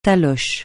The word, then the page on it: taloche: francais